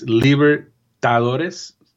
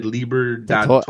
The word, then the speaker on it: Libertadores: You kind of you transposed the T and the D. It's Libertadores. You said Libertadores.